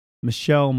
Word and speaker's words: Michel: Michael or Michelle? – Well, it's spelled M-I-C-H-E-L. It's Michelle Martin.